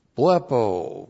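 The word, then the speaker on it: blepo (βλέπω): And then we have blepo. Blepo, B-L-E-P, long O, blepo.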